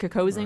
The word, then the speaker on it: Kokosing: And was it called the Kokosing? Is that how you say it? Kokosing right. Water Trail? – Yep, I did that. The Kokosing River Water Trail is just absolutely beautiful, as Aaron said.